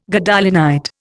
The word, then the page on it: Gadolinite: Indian